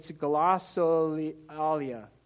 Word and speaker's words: glossolalia: It's glossolalia. Glossolalia. Let me just spell it. G-L-O-S-S-O-L-A-L-I-A.